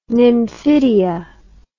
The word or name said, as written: nymphidia